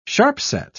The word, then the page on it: sharp-set: British English